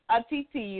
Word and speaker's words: Otiti: Otiti, you there? – I am.